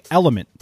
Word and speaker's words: LMNT: Today's show is sponsored and hydrated by Drink Element. That's spelled L-M-N-T, which I've been drinking daily now for weeks.